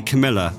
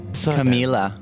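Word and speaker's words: Camila: You don't say Camilla, like off of Royal. – Camilla.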